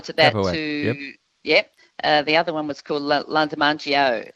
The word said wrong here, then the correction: Lajamanu: I'm always finding it hard to actually pronounce. – Lajamanu. – That's it. You got it.